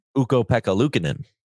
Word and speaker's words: Ukko-Pekka Luukkonen: Ukko-Pekka Luukkonen. – Ukko-Pekka Luukkonen.